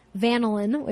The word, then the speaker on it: vanillin: You want to look for, although sometimes artificial vanilla is listed under vanillin, or as my friend Jane Hersey calls it, vanillin, which I think is a fun way to pronounce it.